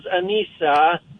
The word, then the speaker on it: Aneesa: Her name is spelled A-N-E-E-S-A. Her name is Anissa, awesome. – Like Lisa, but instead of Lisa, it's called Anissa. It's an Arabic name.